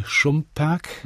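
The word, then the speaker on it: Šumperk: Da setzen Sie sich mit Ihrer Heimat in Nordmähren auseinander, mit Ihrer Heimatstadt Mähre-Schönberg, heute Schumperg, richtig ausgesprochen. Richtig. Ist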